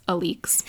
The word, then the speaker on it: Alix: So the premise of the book is that Alix, spelled like Alex, but it's Alix, that says everything you need to know about her.